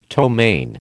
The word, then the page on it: ptomaine: American English